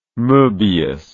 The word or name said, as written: Moebius